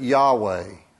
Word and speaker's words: Yahweh: In English, we transliterate it this way, J-H-W-H or Y-H-W-H. We pronounce it Yahweh.